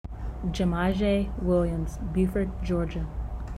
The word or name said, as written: Gamyzhae Williams